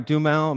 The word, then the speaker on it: Duhamel: Duhamel.